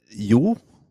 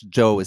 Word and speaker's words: Jo: The correct pronunciation of my name is you, but in English, Joe is fine.